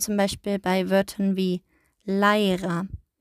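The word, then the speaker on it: læra: Bei Leira, wir als Deutsche, wir sehen da ein A und ein E und wir würden wahrscheinlich irgendwie lesen wie Leira. Aber im Isländischen wird es als AI ausgesprochen und damit ihr wisst, was Leira heißt, also Leira heißt lernen.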